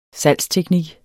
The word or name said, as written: salgsteknik